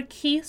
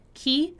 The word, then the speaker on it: key: Já falamos aqui sobre a palavra key, certo? Que é principal, crucial, fundamental, chave. E também reforçando a pronúncia. Key, que é como se fosse aquele I prolongado.